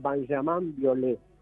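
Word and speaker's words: Benjamin Biolay: Ilia Curiaki o Benjamín Violet que él me enseñó que se pronuncia en realidad Benjamin Violet.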